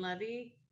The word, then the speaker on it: Levy: But I saw a clip of an interview with Dan Levy. That's how you say his name. – Yes.